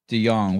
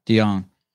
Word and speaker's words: De Jonge: Dallin Pepper, Cedric Lapointe, and Luke DeYoung. Will you pronounce your last name for me, Luke? – It's DeYoung. You said it right. – DeYoung.